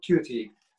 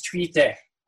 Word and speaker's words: tuite: Is it tuite or tuti? – Okay. So either or is actually fine, because it's just local. How I know is tuite, which is the digging into the cavities of the body.